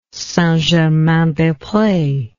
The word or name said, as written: St Germain des Pres